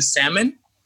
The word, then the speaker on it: salmon: No, but I feel like the Lawrence Hive is comprised of men who pronounce the L in salmon.